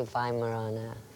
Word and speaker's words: Weimaraner: Most people pronounce it a Weimaraner, but I say Weimaraner.